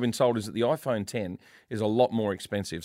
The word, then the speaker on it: iPhone X: So it's called the iPhone X, but it's actually 10. You pronounce it the iPhone 10.